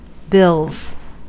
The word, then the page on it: bills: /bɪlz/; American English